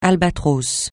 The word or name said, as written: albatros